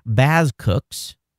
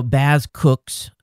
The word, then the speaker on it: BazQux: It's pronounced Bazcooks, B-A-Z-Z-C-O-O-K-S. I asked the developer how to pronounce it, and it's spelled Bazcook, B-A-Z-Q-U-X. Oh, my God. So Bazcooks, B-A-Z-Q-U-X.